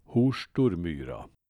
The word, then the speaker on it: Horstormyra: Detta uttal bekräftades senare av Åke Halvarsson i Hole med över 90 års rutin som Holebo. Därav kan vi nog fastställa att uttalet ska bli Horstormyra att efterhandskonstruerad stavning kan ställa till det när det gäller dialektala topografiska namn finns det flera exempel på, som Hedbäckmon.